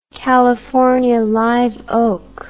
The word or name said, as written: California live oak